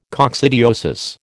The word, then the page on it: coccidiosis: American English